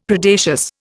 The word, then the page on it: Predacious: Indian